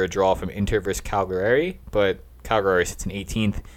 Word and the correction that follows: Cagliari: Cagliari. – Cagliari. Cagliari. – Cagliari. Yeah, come on. G-L-I.